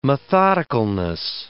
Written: methodicalness

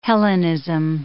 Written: Hellenism

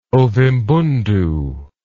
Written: Ovimbundu

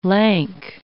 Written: lank

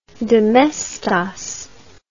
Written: domestos

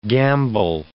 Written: gamble

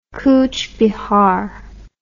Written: kuch bihar

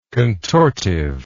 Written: contortively

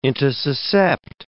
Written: intussuscept

